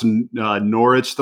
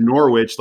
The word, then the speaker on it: Norwich: Hannah, this is a while back, but I pronounced Norwich the wrong way, and they flipped the fuck out, okay? Because there is a W in that word, and I said Norwich like sandwich, and they all just...